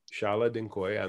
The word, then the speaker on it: Chala Dincoy: And because of that, I'm really lucky to have Chala Dincoy on the, and I hope I pronounced it right, on the show.